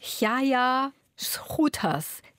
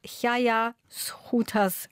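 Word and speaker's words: Gaea Schoeters: So in etwa spricht man die Autorin aus, eine flämische Autorin. Es schreibt sich Gaea Schöters, falls jemand das Buch im Buchhandel sucht. Es wird in etwa Jaja Schruters gesprochen.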